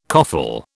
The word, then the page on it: coffle: British English